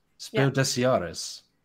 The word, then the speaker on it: Spiel des Jahres: And yet always pronounces it like it's French. – Spiel des Jahres. – Des Spiel des Jahres. – You say the S. Des. – Des. Spiel des Jahres.